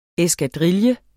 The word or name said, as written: eskadrille